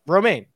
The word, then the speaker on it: Romain: No, you said Roman. – Romain, like the Lex. Like the salad. – You got to roll the R. – Romain.